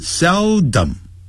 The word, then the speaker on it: seldom: He seldom, 好，这个字要念成 seldom, sell, 再来 dom。